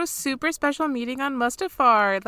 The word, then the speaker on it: Mustafar: to Mustafar, which is maybe how you say it. – Yeah, that is how you say it. Yes.